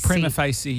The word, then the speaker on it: Prima Facie: Prima facie, yeah.